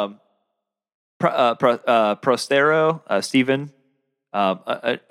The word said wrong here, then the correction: Prothero: Yeah, Prothero.